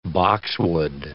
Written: boxwood

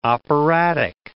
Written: operatic

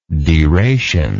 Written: deration

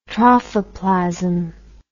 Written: trophoplasm

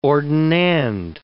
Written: ordinand

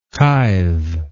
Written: tythe

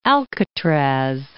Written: Alcatraz

